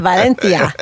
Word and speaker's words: Valencia: We spent the summer in Barcelona, Madrid, and Valencia. – Oh, you even know how to say it. Valencia.